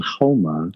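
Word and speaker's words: haoma: And, you know, I mean, even in Zoroastrianism, there's soma, but it's called homa because they didn't pronounce the S. They pronounced it like an H.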